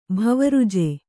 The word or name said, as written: ಭವರುಜೆ